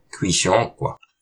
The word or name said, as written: Qihshôq